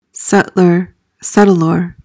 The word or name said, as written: settlor